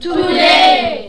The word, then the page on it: today: english